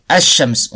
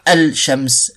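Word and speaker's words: ash-shams (الشمس): Parce que ash-shams, on dit pas al-shams, ash-shams.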